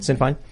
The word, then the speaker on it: Sinn Féin: You know, Irish Republicanism, where now the Republican Party is Sinn Féin. Is that how you pronounce it? – Yes. Yeah, Sinn Féin.